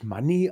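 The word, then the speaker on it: Moné: Äh, ihr Theme, da wird sie irgendwie offensichtlich Money ausgesprochen, also faszinierend.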